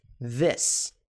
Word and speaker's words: this: A primeira é this. This. Perceba que o som se assemelha mais ao som de E.